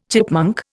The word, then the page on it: chipmunk: Indian